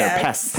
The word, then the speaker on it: pests: And plural, it's a tough one because you got that STS all together. Pests. – Pestices. – You really have to pronounce it. – That T. – Yeah. – It gets lost.